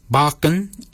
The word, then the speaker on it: baginy: Barkin is spelled B-A-G-I-N-Y. Barkin. Barkin is your beard. The upper mouth, we say warrigal. Warrigal. Warrigal is spelled W-O-R-R-A-G-O-L, Warrigal.